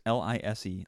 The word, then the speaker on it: Lise: And in my mind, it was L E E S E, but no, uh, when I look it up, it's L I S E, Lise yeah. Hampton. And he pronounces it Lise.